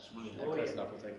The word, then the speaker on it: Schmulian: Shmulian. – Spell it. – Can't. In Hebrew or English. S-C-H. – What? – S-C-H-M-U-L-I-A-N. – Shmulian? – Shmulian.